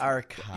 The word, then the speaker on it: Arcata: It's Arcata.